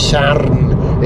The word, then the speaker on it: Sarn: They're actually in a village called Sarn, which, if you're not Welsh, you'd probably pronounce Sarn, but it's Sarn if you're Welsh. You've got to roll the R.